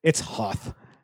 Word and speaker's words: Hoth: Or is it Hoth? – It's Hoth. – What if it's Hoth? – It's not. They pronounce it Hoth in the movie.